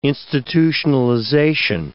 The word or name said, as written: institutionalization